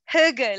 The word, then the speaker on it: hygge: It's, I was telling some friends about it recently and I was like, no, it's not pronounced that way. Like I had to learn to be like hygge, like it comes from your like throat.